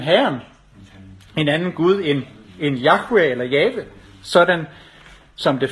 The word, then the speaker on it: Yahweh: For når Aaron siger Herren, ja, så bruger han faktisk det navn, der er gengivet som YHWH på hebraisk, og som de fleste lærte i dag mener skal udtales Yahweh eller Jave, som vi siger på dansk.